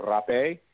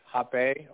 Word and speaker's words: rapé: The other types of plants that we use in a ceremony, one is called rapé, or hape, or hapelita. It's spelled R-A-P-E, like rape, but an accent on it, so don't get it confused with the way it's spelled.